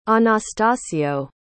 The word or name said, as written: Anastasio